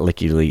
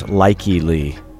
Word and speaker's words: Likey Lee: We've been pronouncing her name wrong all this time. It's not Licky Lee either. Gustav isn't doing it right. It's Likey Lee. Likey, like Likey, Mikey, Likey.